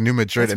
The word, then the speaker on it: New Madrid: Like, in Illinois, there's a New Madrid, but it's pronounced a little bit differently. – Really? – Yeah, there's New Madrid.